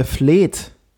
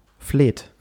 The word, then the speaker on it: Fleet: äh, woher kommt denn der Begriff äh, Fleht? E e F-L-E-E-T. Fleht. Das heißt, es wird aber auch fleht und nicht Fleet ausgesprochen?